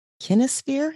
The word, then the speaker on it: kinesphere: And you write about the impact technology has on our kinesphere. Am I saying that right? – Mm-hmm.